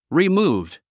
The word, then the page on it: removed: rimúːvd; リィムーブド